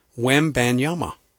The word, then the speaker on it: Wembanyama: I just said, make it simple. It's Wembanyama. Easy. But I hear I hear kumbaya. I mean, I hear all sorts of stuff.